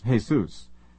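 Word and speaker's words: Jesús: The Spanish pronunciation is Jesus, although most Americans pronounce it as if referring to the Christian Savior.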